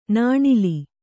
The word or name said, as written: ನಾಣಿಲಿ